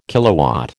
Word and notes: kilowatt: American English

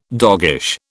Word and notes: doggish: British English

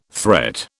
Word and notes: threat: British English

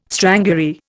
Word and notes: strangury: Indian

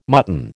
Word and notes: mutton: American English

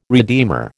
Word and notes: redeemer: American English

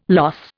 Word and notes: loss: Indian

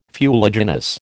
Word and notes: fuliginous: American English

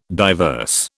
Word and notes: diverse: British English

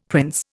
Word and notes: prince: Indian

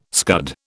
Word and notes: scud: British English

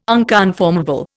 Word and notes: unconformable: Indian

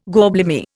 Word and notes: gorblimey: Indian

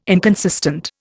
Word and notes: inconsistent: Indian

